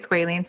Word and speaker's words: squalene: And that's squalene. Is that how you say it? Squalene? – Squalene. You can say squalene. Everybody kind of says it differently.